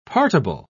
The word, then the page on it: partible: British English